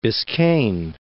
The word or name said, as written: Biscayne Bay